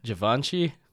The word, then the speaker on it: Givenchy: Givenchy. Is that right? – Givenchy.